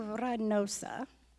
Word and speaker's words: Reynosa: We went into Reynosa. You have to roll your R's.